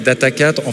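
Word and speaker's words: Data4: Donc moi, je prononce en fait Data 4, en fait, avec le... puisqu'on est très local, on dit Data Quattro, Data 4, Data Sphere.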